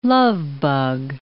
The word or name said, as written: lovebug